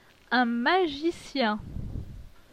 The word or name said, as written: Un magicien